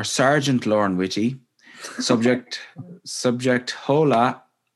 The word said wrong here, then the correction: hola: or Hola, sorry, I know the H is silent.